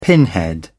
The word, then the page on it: pinhead: British English